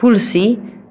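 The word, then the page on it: anak ayam: Malay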